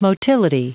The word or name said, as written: motility